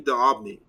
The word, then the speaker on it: D'Aubigny: Her name was Julie Diab. Hold on. Julie D'Aubigny.